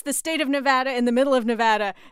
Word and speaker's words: Nevada: the state you live in. How do you pronounce it? Nevada. But I laughed when Trump said Nevada.